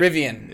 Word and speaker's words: Rivian: Yeah, I know with the top with the top step flyer this year, we bought some, how do you say it? Rivian, right? – Rivian.